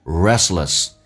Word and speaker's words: restless: 你要记住 rest 的 T 是可以不发音的，两个辅音叠加在一起，所以这个单词就变成 restless.